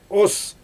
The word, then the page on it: os: Xyzyl